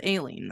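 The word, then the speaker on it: Alline: It's A-L-L. I-N-E. Aileen? Aileen? – Aileen? – Okay, I'm going with Aileen then.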